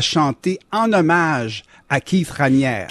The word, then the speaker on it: Raniere: Vous prononcez Raniere. – Ouais. Ben, Raniere, vous avez raison. – Je le prononce à la française.